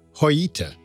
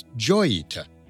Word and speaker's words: Joyita: Interestingly, Roland's original name for the vessel was pronounced Joyita, which is grammatically correct in Spanish. But when the vessel became globally famous in 1955, it came to be mispronounced as Joyita so often that the false pronunciation became common for the ship.